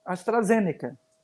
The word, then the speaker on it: AstraZeneca: AstraZeneca.